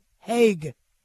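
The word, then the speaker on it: Hague: Apparently, it's pronounced Nicholas Haug. No, excuse me, Haig.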